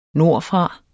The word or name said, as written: nordfra